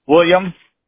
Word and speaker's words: William: William, W-I-L-L-I-A-M. My middle initial is H. My last name is O-A-T-H-O-U-T.